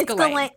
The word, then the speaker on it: Ghislaine: Is that how you say it? – Oh, my God. I should know this because I've been listening to so many podcast episodes about it that I should fucking know. – I hope it's Ghislaine.